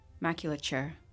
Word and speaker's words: maculature: Maculature. Final answer. – He made the A too long. Maculature.